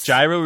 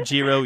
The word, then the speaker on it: Gyro: Gyro or Giro, you're okay.